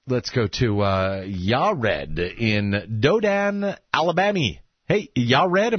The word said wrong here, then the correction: Dothan: Yared in Dothan, Alabama.